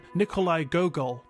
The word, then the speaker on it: Nikolai Gogol: Apparently, aboard this train was a rosewood case carrying the skull of a renowned Ukrainian writer, Nikolai Gogol.